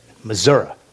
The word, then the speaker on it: Missouri: That's how you say it, Missouri.